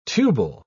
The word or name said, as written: tubal